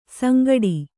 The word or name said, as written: ಸಂಗಟಿ